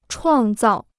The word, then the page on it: 创造: chuàng zào